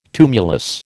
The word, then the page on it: tumulus: American English